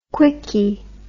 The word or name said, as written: quicky